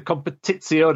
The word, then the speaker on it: Competizione: E-T-I-Z-I-O-N-E. – I think so. I think you're It's very easy to add an extra T into Competizione, because that's how you pronounce it, right? – Yeah, yeah.